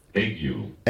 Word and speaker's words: ague: Um, let's just check how it's, it's pronounced. Um, ague. – Ague, okay. Um, and it's described as a febrile condition in which there are alternating periods of chills, fever, and sweating.